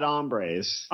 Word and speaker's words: hombres: Did he actually pronounce the H? – No, he said bad hombres. – Hombres. – Oh, okay. – Hombres. I said hombres. – Yeah. – Would have been much funnier if he said hombres. – Silent H.